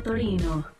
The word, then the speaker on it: Torino: italiano se pronuncia Torino.